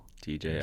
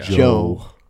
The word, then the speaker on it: DJO: DJO. – Joe. It's pronounced Joe.